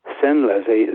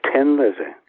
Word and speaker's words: Thin Lizzy: And I thought, right, Tin Lizzie, if we put an H in there, Thin Lizzie, the people in Dublin are st- still going to pronounce it Tin Lizzie. Yeah. And I would say, no, it isn't. It's thin.